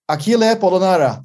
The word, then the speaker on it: Achille Polonara: As our talk continues, we have a new, very special guest, Achille Polonara.